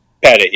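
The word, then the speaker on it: Petit: Is it Pettit? Is that how you say it? – Pettit, yeah.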